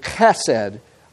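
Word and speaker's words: Chesed (חסד): It is actually the Old Testament word chesed. I've tried to spell it as close as I can in English so you get the idea. It's almost a K sound on the front. Chesed.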